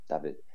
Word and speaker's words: David: But yeah, definitely pronounced David.